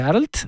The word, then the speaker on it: Arlt: Entonces, satisfecho de ponerlo en un apuro al pedagogo, le dictaba A-R-L-T cargando la voz en la L.